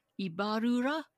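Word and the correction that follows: Ibárruri: Ibarruri? – Yeah, La Pasionaria.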